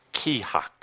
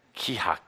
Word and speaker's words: Kihak: It is pronounced Kihak. Kihak. That sound fun? You can say it. Kihak. Yeah. Kind of like saying shit.